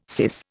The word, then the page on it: syce: Indian